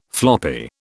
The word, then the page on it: floppy: British English